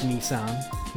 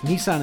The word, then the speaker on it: Nissan: Now, Nissan, of course, Nissan. Nissan is how you say it in the United States. I will say Nissan. Uh, because that's how we said it in England when I grew up.